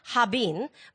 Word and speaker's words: Jabin: Now, it's pronounced Habin, but we are English-speaking, so I'm just going to say Jabin.